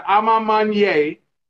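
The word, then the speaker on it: A Ma Maniére: So I'm going to say it right for y'all, for everybody that's struggling with it. It's the Ama Manier. Now, I mean, it's French.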